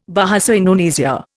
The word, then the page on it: Bahasa Indonesia: Indian